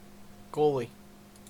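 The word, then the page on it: goalie: Canadian English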